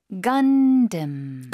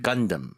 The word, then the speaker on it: Gundam: Gundam. – Gundam. – Gundam. – 앞쪽에 힘 주고 뒤쪽에 살짝 약화시켜서. 네. 영어식 발음입니다.